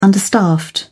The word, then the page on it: understaffed: British English